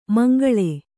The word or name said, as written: ಮುಂಗಾಳು